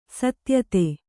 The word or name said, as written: ಸತ್ಯತೆ